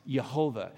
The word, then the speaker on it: Yahweh: L-O-R-D, as it is in Exodus 20, verse 7, that is, in the original language, the word Yahweh, or as later it began pronounced, Yehovah.